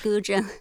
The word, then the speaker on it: guzheng: Now, you're performing on your compositions on an instrument, and I, again, my pronunciation is terrible, but it's the Guzheng. – Yes, Guzheng.